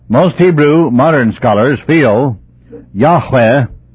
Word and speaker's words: Yahweh: The true pronunciation seems to have been Yahweh. The accent should be on the final syllable.